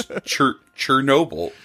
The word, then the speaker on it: Chernobyl: How did you say it? – Chernobyl? – Chernobyl? – Chernobyl? – Chernobyl? It's Chernobyl.